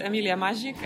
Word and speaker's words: Emilia Mazik: My name is Emilia Mazik and I come from Poznań, Poland.